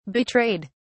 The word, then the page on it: betrayed: British English